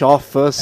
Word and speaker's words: of us: Rapidinho, no off, no off, no Last of Us. É óbvio. É off. É, então, é só juntar, of us. Aí fica of facinho, cara.